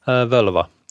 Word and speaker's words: völva: So is that how you say it? Vulva? – Velva, yeah. – Velva. Okay, that's V-O-L-V-A, and that means like a Viking seeress, like a, you know, witch or right? – Yeah, that's true.